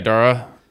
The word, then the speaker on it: Dara: Yeah, Dara.